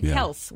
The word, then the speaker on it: Kelce: They said in an interview a couple years ago, it's Kelce. We started, like, people, the media started saying Kelce. They pronounce it Kels, which is random, but anyway.